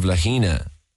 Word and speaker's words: Lahaina: They've killed 36 people thus far, and when you look at the comparisons, you know, like the town of Lahaina, should I say, to pronounce it correctly.